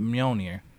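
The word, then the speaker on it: Mjölnir: Because the first Black Panther wielded Mjolnir.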